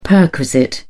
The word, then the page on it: perquisite: British English